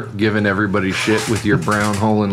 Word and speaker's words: Braunohler: It's Braunohler.